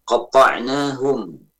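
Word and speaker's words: قَطَّعْنَاهُمْ: Kemudian qatta'nahum, qatta'nahum dengan mentasdidkan ta'nya. Ini pada surat Al-A'raf ayat 160 ya. Hati-hati pengucapan qatta'nahum ya, tasdid, jangan sampai terbaca tanpa tasdid, qatta'nahum ya.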